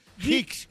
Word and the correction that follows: Hicks: Ah, Hicks. H-I-C-K-S.